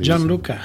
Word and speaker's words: Gianluca: Gianluca.